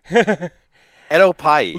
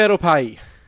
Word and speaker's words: Edokpayi: Ed Opai. – Lugard Ed Opai. – Okay. So the K is silent.